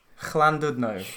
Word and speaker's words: Llandudno: How do you say Llandudno? Is it like that? – It's more pronounced on the Llandudno.